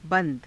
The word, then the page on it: ba^da: Hindi